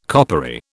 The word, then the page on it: coppery: British English